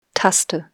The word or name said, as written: Taste